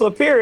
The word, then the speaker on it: Lapeer: Yeah, it's Lapeer. It sounds French, but I don't know where the hell it came from, really. Yeah, Lapeer, yep. Yep. Sure did. That's how you say it.